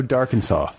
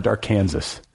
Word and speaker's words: Darkansas: So Darkansas. Am I pronouncing that right? – Yeah. – It's not Dark Kansas. – No, it is not. Okay. Arkansas. Like so Arkansas with a D.